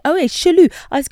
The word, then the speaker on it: chelou: So, C-H-E-L-O-U. – Chelou. And it's not about doing jealous. Jelou. – So it's Verlan. So L-O-U-C-H-E. Louche. – Louche. L-O-U-C-H-E.